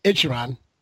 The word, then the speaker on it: Ichiran: A Japanese ramen chain, Ichiran, is promising diners that with its first American outpost in Brooklyn, New York. Ichiran, if you were having to be curious about it, is an omnipresent, thank you for the pronunciation, Joe, brand in Japan.